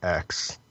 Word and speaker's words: X: I think they pronounce it X. Daemon X Machina. – It's not cross. I learned the letter X is always cross in Japanese game titles, so this is surprising.